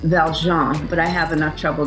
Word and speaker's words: Valjean: Okay, I was like, oh God, I have said Valjean on the podcast, so I will. – That's okay. That's the really correct pronunciation.